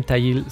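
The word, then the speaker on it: Thayil: Kim Tayil, se, pronuncia así su apellido, en guitarra, o sea, Novoselic, digamos, representando lo que fue Nirvana, Cameron y Tayil representando a lo que fue Soundgarden, ¿no?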